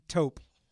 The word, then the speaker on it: taupe: You're wearing taupe. Aqua. – Taupe still rhymes with dope, man.